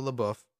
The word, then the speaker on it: LaBeouf: While covering the story, reporter Ben Shepard, Shia LaBeouf, am I pronouncing that right? – Sure.